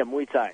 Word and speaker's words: Muay Thai: So so you two basically go, I saw the Muay Thai, is that how you say it? – Yeah, Muay Thai.